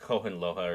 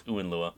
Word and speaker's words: Hohenlohe: Yeah, I would have said like Hohenlohe or something like that. – Yeah, you know? but it's Uenlua apparently the German.